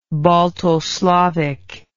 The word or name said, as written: baltoslavic